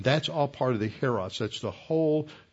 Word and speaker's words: Hieros: Hieros, that is spelled H-I-E-R-O-S, H-I-E-R-O-S, and Naos, N-A-O-S.